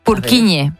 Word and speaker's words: Purkinje: Es que se escribe, portugués. En español sería Purkinje. – Purkinje. – Pero Purkinje es en checo, que es porque es el apellido del descubridor.